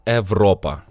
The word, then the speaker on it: Europa: Я думаю, що то на грецький лад Європа.